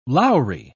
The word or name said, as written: lowery